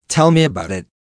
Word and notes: tell me about it: American English

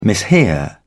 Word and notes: mishear: British English

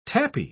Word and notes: tapis: British English

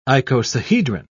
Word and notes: icosahedron: British English

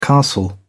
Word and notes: castle: British English

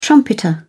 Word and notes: trumpeter: British English

